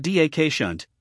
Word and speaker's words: Dachshund: The Dachshund, pronounced D-A-K-Shunt, is an affectionate dog with a big personality.